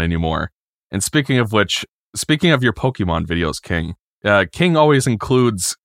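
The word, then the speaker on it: Pokémon: And it drives me absolutely insane that every single one of these commercials pronounce it Pokemon, because I know that's how it's actually supposed to be pronounced, because there's an accent on the E, but nobody I have ever met in real life pronounces it Pokemon.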